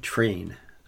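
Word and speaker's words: Træen: And as his fellow breakaway rider, Torstein Train, I looked that up to try to get the, it's spelled Traeen, but they say train. That's what they say.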